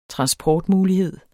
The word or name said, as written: transportmulighed